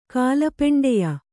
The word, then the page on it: ಕಾಲಪೆಂಡೆಯ: kālapeṇḍeya